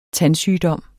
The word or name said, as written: tandsygdom